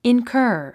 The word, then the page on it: incur: inkə́ː r; インカァ